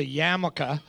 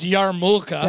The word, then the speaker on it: yarmulke: I love when you start them off this way, because one of the times it was a yarmulke. Which you pronounced Yarmulka. – That's how it's spelled. That's the phonetic spelling.